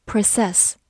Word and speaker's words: process: 这个时候，它的重音放在了后面，读作 process。